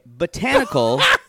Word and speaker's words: botanical: Like it's spelled B-A-U, botanical gardens. Anyway, botanical, because there's a rule in English that says we can have one stressed syllable or two stressed syllables for a long word like that, and it's rarely the first.